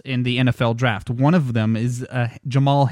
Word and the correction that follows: Jamel: Hamler. – Jamel. – I always say that wrong, don't I? – It's just like Pat Hill.